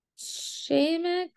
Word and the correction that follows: Chemex: pour over, I think that's how it would be pronounced. It's Chemex. So we'll find out what that is.